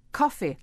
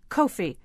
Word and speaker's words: Kofi: She says, coffee, like coffee cup, not Kofi.